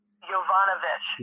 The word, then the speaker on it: Yovanovitch: Yovanovitch. – Yeah, and she... – Yovanovich.